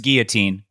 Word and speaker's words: guillotine: It's guillotine. A double L in in there is pronounced like a Y.